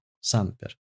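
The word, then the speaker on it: Sumpter: Дэвид Семпер. Санпер, точнее.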